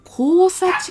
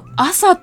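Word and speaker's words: 厚狭: 山口県です、山口県山陽小野田市内では高千穂地域交流センター小野田地区と、あ、これなんて読むんだろう、交差地区でいいんですかね。あ、違う、朝って読むんだ。朝地区なんですね。へえ、暑いと書いて、暑い、狭いって書いて朝と読むそうです。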